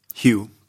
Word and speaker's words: Hue: E, H-U-E, Hue Stavas, eller uttalas det du som kan det här med? Hugh, precis. Tack.